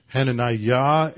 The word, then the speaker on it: Hananiah: Now when I said two of those names, Hananiah and Azariah, I'm emphasizing the last syllable. In fact, in Hebrew, the last syllable would have been accented anyway.